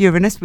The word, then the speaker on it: Uranus: Do you mean Uranus? – No, it's Uranus. We checked pronunciation. – I prefer it as Uranus. – We had it verified.